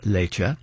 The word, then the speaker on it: Leite: again spelled L-E-I-T-E, pronounced Lecce,